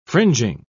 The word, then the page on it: fringing: British English